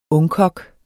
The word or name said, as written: ungkok